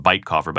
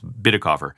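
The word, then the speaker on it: Bitecofer: Sounds like it should be pronounced Bitecoffer, but it's Bitticoffer.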